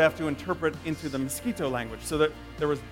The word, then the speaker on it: Miskito: And that's spelled M-I-S-K-I-T-O, Miskito.